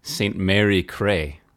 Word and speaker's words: St. Mary Cray: As in, do you know that St. Mary? She be Cray. – Is that really? Is it C-R-A-Y? Is that how it's spelled? – It's C-R-A-Y, yes.